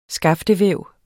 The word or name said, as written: skaftevæv